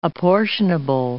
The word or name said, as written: apportionable